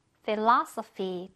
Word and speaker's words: philosophy: 요거 무슨 뜻이냐면 철학이란 뜻인데 얘도 지금 ph가 두 군데 들어가 있습니다. 이렇게 들어가 있는데 역시나 둘다 ㅂ 발음으로 넣어주면 되겠습니다. philosophy 한번더 philosophy 뜻은 뭐라고?